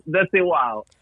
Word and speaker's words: Desigual: This is called Desidual? Desidual? – Yeah. – Oh, dude. – Desigual. – Oh, wait. Say it again. How do you say it in your language? – Desigual.